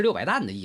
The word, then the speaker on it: 石: 石就是石头的石啊，明清以后也多念作担，挑那挑，担那担子，那个担谐音，在古意中有计量之意啊， 600石也是 600担的意思。